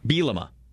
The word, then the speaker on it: Bielema: He's like his, how do you say his last name? – Bielema.